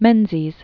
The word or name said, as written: Robert Gordon Menzies